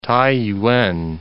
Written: Taiyuan

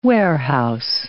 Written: warehouse

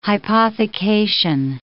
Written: hypothecation

